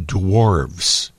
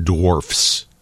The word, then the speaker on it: dwarves: And also, Shawnee, this text in from the 847, it's pronounced dwarves, not dwarfs. – Ah, with a V.